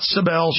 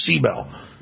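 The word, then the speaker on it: Sibel: Sometime today, after Ron Paul comes on and we talk to Cybill Edmonds, people keep saying, why do you say Cybill? She says, that's how you pronounce it. Not Cybill, she says Cybill.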